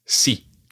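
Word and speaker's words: sí: It's very straightforward. I'm quite sure everyone knows this. It's si. – Si. – Si. – Si. – Si. – Si. – And if you're writing si, it's spelled S-I and the I has an accent on it. And it's an acute accent, which means it goes up to the right.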